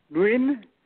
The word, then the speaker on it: Nguyen: My name is Nguyen N W I N and my email is Nguyen, N-W-I-N, Tran, one word.